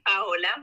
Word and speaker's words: Paola: So so that's how you say my name, Paola. – Paola. – It's not Paola. It's not Paola. Okay, Paola. So it's P as in Peter, letter A, letter O, L A, underscore, RDH, dot 15.